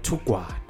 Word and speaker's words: Tukwana: But when we call it out ourselves, we say Tukwana. – Tukwana. So this is a is this how it's spelled on your ID? – In my ID. – Exactly like this. – Yes, exactly and exactly like this, except for the E. – Tungwana. – Yes. With, I'm very sorry, man. I recognize such things. I can't mispronounce your name and not you know, apologize. So it's Bongani Tukwana. Am I saying it properly? – Tukwana, that's my name.